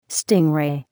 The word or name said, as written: stingray